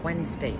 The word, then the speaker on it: Wednesday: Пишемо Wednesday, читаємо Wednesday. Теж правило діє у sandwich і Hanson.